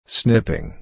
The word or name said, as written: snipping